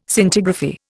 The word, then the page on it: Scintigraphy: Indian